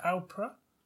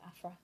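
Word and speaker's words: Aphra: Doctor Alpra? – Afra. Is Afra? It Afra? Help me out. Some sort of Star Wars situation. Yeah, there you go. Afra. – Afra.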